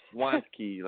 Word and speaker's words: Wanky: Yeah, it's just Wanky, like I want a key.